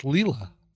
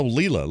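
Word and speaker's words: Leila: We saw it on the Facebook tadpole, and I just thought people are correcting us that in this case it's pronounced Leela. – Oh, Leela, like Leela from Futurama.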